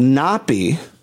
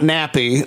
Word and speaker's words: Napi: And we might even see one of the Native American character, Chief, played by Eugene Brave Rock, introduces himself to her as Napi. – How do you spell that? – Not Nappy, like your hair. N-A-P-I, the Blackfoot demigod, who's known as a trickster and storyteller.